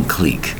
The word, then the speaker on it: clique: I'm in my own clique, my own type of club. The word is clique. One syllable for the word clique, spelled C-L-I-Q-U-E. Clique. Unusual word, isn't it? It's a French word.